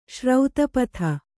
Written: ಸರಿತ್ಪತಿ

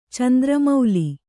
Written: ಚಂದ್ರಮೂಲ

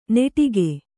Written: ನೆಟಿಗೆ

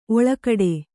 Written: ಓಲೆಗೊಡೆ

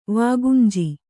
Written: ವಾಗುಂಜಿ

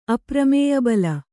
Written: ಅಪ್ರಮೇಯಬಲ

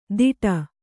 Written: ದಿಡ

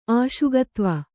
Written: ಆಶುಗತ್ವ

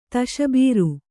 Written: ತಶಬೀರು

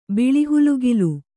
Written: ಬಿಳಿಹುಲುಗಿಲು

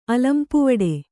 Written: ಅಲಂಪುವಡೆ

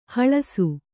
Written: ಹೊಳೆಸು

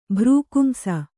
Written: ಭ್ರಕುಂಸ

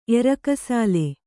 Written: ಎರಕಸಾಲೆ

